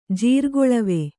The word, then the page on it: ಜೀರ್ಗೊಳವಿ: Kannada